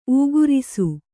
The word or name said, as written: ಊಗುರಿಸು